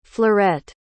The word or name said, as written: fleurette